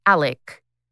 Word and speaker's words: Alex: See, nothing spelled the way, because it's spelled Alex with an X, but it's pronounced Alec.